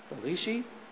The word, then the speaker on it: Velshi: so I'm going to spell it first. V-E-L-S-H-I. Velishi.